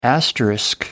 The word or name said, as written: asterisk